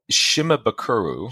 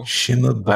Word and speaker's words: Shimabukuro: Shimabukuro. – Shimabukuro.